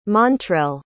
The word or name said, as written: montrell